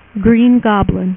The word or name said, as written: Green Goblin